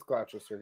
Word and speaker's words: Gloucester: It's Gloucester, yeah.